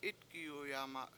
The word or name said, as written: Itgiyuyama7